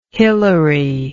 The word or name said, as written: hilary